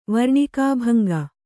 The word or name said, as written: ವರ್ಣಿಕಾಭಂಗ